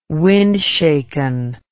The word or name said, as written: windshaken